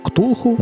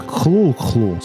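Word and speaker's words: Cthulhu: Ім'я найвідомішого персонажа Лавкрафта Ктулху насправді правильно вимовляти як Хлулхлу.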